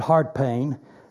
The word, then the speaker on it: Howard Payne: We pronounce it Hard Payne. That's how they say Howard in Texas.